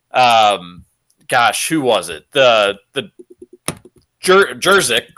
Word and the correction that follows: Juszczyk: what's how you pronounce his last name Juszczyk yeah You.